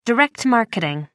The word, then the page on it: direct marketing: American English